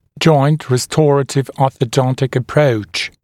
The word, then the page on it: joint restorative-orthodontic approach: [ʤɔɪnt rɪ’stɔrətɪv-ˌɔːθə’dɔntɪk ə’prəuʧ]; джойнт ри’сторэтив-ˌо:сэ’донтик